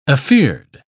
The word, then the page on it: afeard: British English